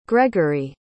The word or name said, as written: gregory